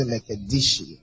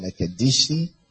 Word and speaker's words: Mekadesh: Some people say Mekadishi, but I didn't write that one. Mekadesh. Mekadesh or Mekadeshi.